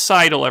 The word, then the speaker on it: Seidel: Molly Seidel, and it's pronounced Seidel, everyone, just for future reference, 2nd place in her debut, the most shocking result of the day for me, undoubtedly.